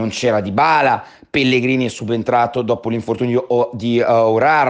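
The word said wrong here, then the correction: Aouar: eh, che comunque, Awar, meglio precisare la pronuncia,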